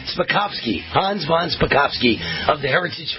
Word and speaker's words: Hans von Spakovsky: Let me make sure I'm saying this right. Hans von Spakovsky. Am I saying that right? – You got it, yeah.